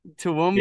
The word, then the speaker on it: Toowoomba: Toowoomba, is that how you pronounce it? – Yeah, something like that. – Yeah. – Good job. – Toowoomba.